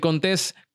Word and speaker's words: Contés: Mi nombre es Miguel Contés, con acento en la E, y nos vemos en la próxima.